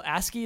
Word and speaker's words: ASCII: But it's all ASCII, is how you pronounce it? – Yeah. – ASCII graphics, Man, that's a fun little game.